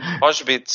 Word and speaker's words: Auschwitz: Así que solicitó trasladarle al campo de concentración de Auschwitz. Si se pronuncia, sí, ah, si no, perdón. Auschwitz, sí, sí, está bien.